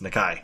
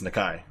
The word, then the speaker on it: Nakai: Yeah, it's not pronounced like Nakey Jakey. It's Nakai. That's that's that's how I've been pronouncing it.